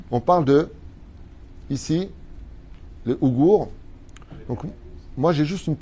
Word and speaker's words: Ouïghours: D'après donc les Uyghurs, comme ça qu'on prononce Ouïghours, autant pour moi, d'accord euh, Basés à Washington.